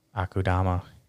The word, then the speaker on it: Akudama: I'm doing Sigurd one in Akamuda Drive. – Akudama. – I know. – You fucking say it right.